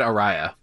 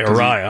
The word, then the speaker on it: Araya: Vocalist Tom Araya, is that how you pronounce his name? – I've always said Araya. – Okay, Araya.